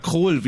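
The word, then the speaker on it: Król: Und dann kommt Joachim Kroll, lange nicht mehr gesehen, und spielt den Exorzisten, oder Kroll, wie er ausgesprochen wird, Joachim Kroll, und spielt den Exorzisten und stirbt aber bei dem Versuch, Armin Rohde aus deiner Wohnung, stirbt dabei.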